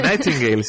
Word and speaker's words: Nightingale: I sembla que la data escollida commemora el naixement de Florence Nightingale, no sé si ho dec pronunciar bé. Has dit molt bé, Nightingale, sí senyora.